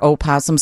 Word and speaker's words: opossum: it is a opossum because Wikipedia claims that the O is silent. – Several people, including my wife, told me that yesterday, that you don't pronounce the O.